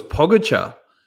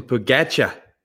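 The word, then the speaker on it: Pogačar: Um, it was interesting, uh, Phil's pronunciation of, uh, I thought it was Pogacar, but he calls him Pogacar.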